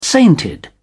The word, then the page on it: sainted: British English